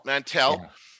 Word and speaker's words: Mantell: So Sid owns this cantina on Ord Mantell is the pronunciation of Mantell.